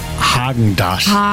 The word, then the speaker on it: Häagen-Dazs: Spricht man das mit R oder mit A aus? – Man spricht es mit A aus. Ich sage auch, es heißt Häagen-Dazs.